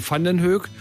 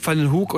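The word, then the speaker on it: Vandenhoeck: Und dieses Vandenhoek, das kann man dreifach verschieden aussprechen. Sie können sagen Vandenhoek, Vandenhoek oder Vandenhoek. Alles möglich.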